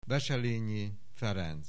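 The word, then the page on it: Wesselenyi Ferenc: Hungarian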